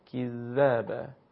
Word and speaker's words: کذب: نرم و خیلی بدون ضربه باید حرف ادا بشه، کذب.